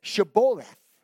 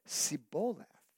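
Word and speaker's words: Shibboleth: Tell them to say the word river, or Sheboleth, said Jephthah. Why? Because the men of Ephraim could not pronounce the sound of SH. So instead of Sheboleth, they said Siboleth.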